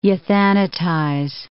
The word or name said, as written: euthanatize